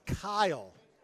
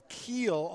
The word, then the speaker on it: Kiel: My name is K-I-E-L, pronounced Kyle. – Okay. – I get called Keel on a daily basis.